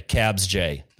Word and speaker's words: KABZJ: And Matt had received the torch from, looks like Kabaz, at KABZJ. It says, "Success at Matt Odell. You should have the torch." Okay, so Cabs. Okay, Cabs J. We'll pronounce it that way.